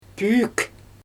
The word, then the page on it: püük: Xyzyl